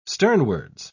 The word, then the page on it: sternwards: British English